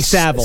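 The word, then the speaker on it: Savile: Is it Saville? – I think Saville. – It's Jimmy Saville.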